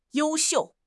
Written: you1 xiu4

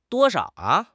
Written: duo1 shao3

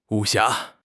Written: 武侠

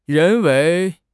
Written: 人为

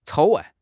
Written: 草案

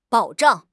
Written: 保障

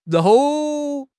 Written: 搂

